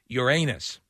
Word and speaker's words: Uranus: We're looking at it like, for example, here it says how to pronounce Uranus, and it says you're with a long vowel sound over the A, Uranus. – Yeah. – Really and truly, I think both are considered acceptable.